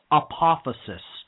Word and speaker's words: apophasis: And I'll spell it A-P-O-P-H-A-S-I-S, apophysis.